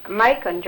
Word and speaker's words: Macon: Rhymes with bacon. If that helps any. – I was thinking about Macon, Georgia, right.